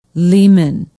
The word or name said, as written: Leeman